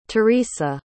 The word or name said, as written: theresa